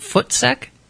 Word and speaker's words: voetsek: And it's spelled V-O-E-T-S-E-K, but it's pronounced like your feet, like footsack? – Yes, because the V in Afrikaans is pronounced as an F. – Oh, okay. – So, footsack. And double O in English is O-E in Afrikaans. So it is F-O-T-S-E-K.